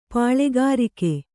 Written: ಪಾಲುಗಾರಿಕೆ

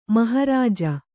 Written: ಮಹಾರಾಜ

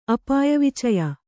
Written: ಅಪಾಯವಿಚಯ

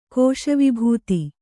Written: ಕೋಶವಿಭೂತಿ

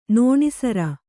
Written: ನೋಣಿಸರ

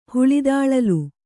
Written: ಹುಳಿದಾಳಲು

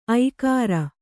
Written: ಐಕಾರ

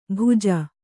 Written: ಭುಜ

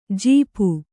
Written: ಝಾಪು

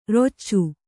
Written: ರಚ್ಚೆ

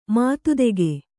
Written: ಮಾತುತೆಗೆ

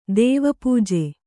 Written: ದೇವಪೂಜೆ

